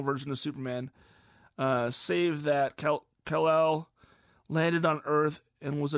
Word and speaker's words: Kal-El: It's spelled K-A-L-E-L, no space. So it's Kal-El, but with no space between the Kal and the L.